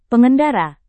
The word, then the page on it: pengendara: Indonesian